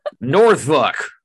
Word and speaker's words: Norfolk: Is it the same way with Norfolk? Is it? – Yes. – Norfolk. Come on down to Norfolk. We get all Norfolky up here.